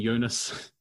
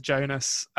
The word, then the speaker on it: Jonas: So from them we get, Jonas, who I'm sure I'm going to call Jonas and Jonas. – I've already committed to Jonas. I'm sure, I'm sure it's a soft J, but it's just not going to happen for me, I don't think.